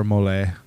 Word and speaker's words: Mollet: MOLLET, Mole. – So we got to pronounce it mole.